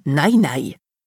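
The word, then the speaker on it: Nainai (奶奶): Il a d'ailleurs insisté pour que Noah m'appelle grand-mère en chinois, Nainai, qui se prononce Nainai.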